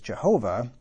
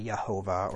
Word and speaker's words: Jehovah: And so YHWH is pronounced as Jehovah, because the Y is pronounced as a J. You could also pronounce it as a Y, Yehovah or Jehovah.